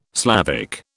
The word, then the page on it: Slavic: British English